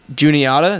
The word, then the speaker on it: Juniata: Juniata, is that how you say it, college? – Yep.